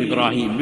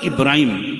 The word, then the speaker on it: Ibrahim: And it's not Ibrahim, how we generally say it and spell it also. It is Ibrahim, it is Ismail, not Ibrahim and Ismail. So we need to correctly pronounce these names when we refer to anyone by that name, call them by the correct name, Ibrahim, Ismail, properly as it's supposed to be called in the Arabic language, to the father of compassion.